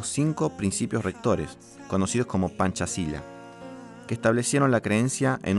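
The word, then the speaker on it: Pancasila: Panchasila Así es como se debe pronunciar.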